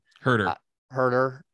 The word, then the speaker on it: Huerter: And then he was forced to foul, and Kevin Huerter went down and knocked down a couple of free throws, which, hey, I don't know how to say his name. – Huerter. – Yeah. – Huerter. Okay. Don't hurt her.